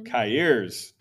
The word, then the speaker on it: Cahiers: Cahiers. Did I say that right? C-A-H-I-E-R-S. Yes.